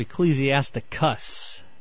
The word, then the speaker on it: Ecclesiasticus: I'm wondering if maybe you're talking about Ecclesiasticus. – Ah, yes, that is how it's said.